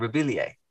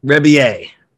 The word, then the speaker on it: Rebillet: How do you pronounce the second name? Rebillier? – Yeah, I've been murdering it for years. I think it's Rebillet.